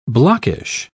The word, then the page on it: blockish: British English